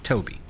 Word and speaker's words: Tobe: All my life I've been saying Tobe Hooper. And I sometimes hear people saying Toby, and I'm like, no, there's no way that he'd spell it that way. – It's confusing. – But it is Toby. – Yeah. – I heard him introduce himself on these commentary tracks. It's Toby.